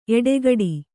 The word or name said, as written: ಎಡಗಡೆ